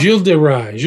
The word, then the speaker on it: Gilles de Rais: How do you say his name? Gilles de Rais? – Gilles de Rais. He's known by different names. Bluebeard, Gilles de Rais, Gilles de Retz. R-E-T-Z.